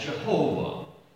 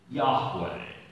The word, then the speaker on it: Yahweh: Now, our parents learned this name as Jehovah. We now are taught to pronounce it Yahweh.